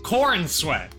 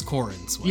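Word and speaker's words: Corenswet: And for Superman, I feel like Cornswet is fitting. – Yeah. Yeah. Cornswet. – Yes, it's Cornswet. – Yes, he would insist on the three syllables, I'm sure.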